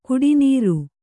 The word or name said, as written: ಗುಟ್ಟುನೆರೆ